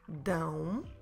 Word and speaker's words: dão: So now we have this ending on ão. Elas dão. Make sure you close your mouth at the end.